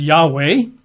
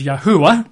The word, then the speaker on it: Yahweh: Some people pronounce it as Yahweh, you know, Y-A-H-W-E-H, Yahweh, that's really quite popular. Quite a few pronounce it as Yahuwah. And they say, no, there's three syllables for sure.